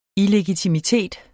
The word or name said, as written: illegitimitet